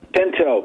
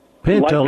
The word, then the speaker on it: Pinto: Pinto, P-I-N-T-O. – How do you spell it? – Pinto, like the horse, a pinto horse. Pinto. – Yeah. – P-I-N-T-O.